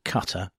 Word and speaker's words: Qatar: all of you, will know that with the 2022 Qatar World Cup currently taking place, or Qatar, if I'm supposed to pronounce it properly,